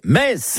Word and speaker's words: Metz: Le meilleur moyen de mémoriser la prononciation correcte de la ville de Metz est certainement de se rendre au stade Saint-Symphorien, où vous répéterez jusqu'à plus soif. Ici, c'est Metz.